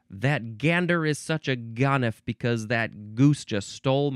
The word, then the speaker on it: ganef: Now, it looks like you pronounce it Ganef. It is spelled three different ways, G-A-N-E-F, or G-O-N-I-F, or G-O-N-I-F-F, Ganef.